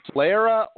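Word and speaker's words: solera: Sclera or Sclera system. I'm not even sure if I said it properly. – You said it correctly.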